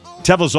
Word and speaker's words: Tevel: Today you can join Our Crowd's investment in Tevel, and I hope I'm pronouncing that right, T-E-V-E-L.